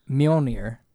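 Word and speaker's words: Mjölnir: But as far as Mjolnir, which is how you pronounce it, mm-hmm.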